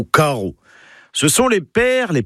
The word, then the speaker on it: karv: Carreau. K-A-R-V. Oui, alors le V, enfin, se prononce O. C'est comme ça, faut l'apprendre.